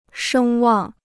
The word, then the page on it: 声望: shēng wàng